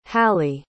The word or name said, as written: halle